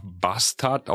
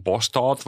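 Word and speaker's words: Båstad: Lars hat mir empfohlen, nach Bastard, auch wieder falsch ausgesprochen, Bostad wahrscheinlich in Schweden, ein tolles, tolles Turnier an der Küste, dass ich da mal hinfahren soll.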